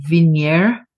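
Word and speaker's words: Vigenère: This, is it pronounced Vigneur cipher? – Yes.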